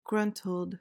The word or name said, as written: gruntled